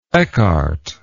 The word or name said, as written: eckhart